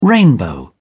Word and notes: rainbow: British English

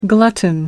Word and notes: glutton: American English

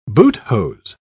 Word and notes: boothose: British English

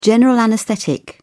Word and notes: general anaesthetic: British English

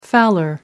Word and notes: faller: American English

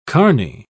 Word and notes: carny: British English

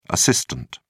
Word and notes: asst: British English

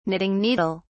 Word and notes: knitting needle: British English

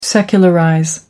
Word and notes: secularize: American English

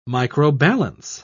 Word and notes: microbalance: British English

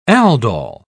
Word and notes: aldol: British English

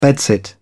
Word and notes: bedsit: British English

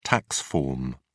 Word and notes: tax form: British English

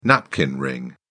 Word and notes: napkin ring: British English

